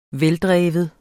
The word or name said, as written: veldreven